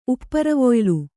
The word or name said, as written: ಉಪ್ಪರವೊಯ್ಲು